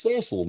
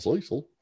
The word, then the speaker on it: Cecil: It's Cecil, not Cecil.